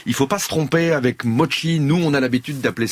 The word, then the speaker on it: mochi: Donc ça se prononce mochi comme s'il y avait un T avant le CH, puisque mochi mochi, c'est complètement différent.